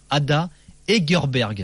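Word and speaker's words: Ada Hegerberg: Je rappelle le nom du premier ballon d'or féminin, Ada Egerberg.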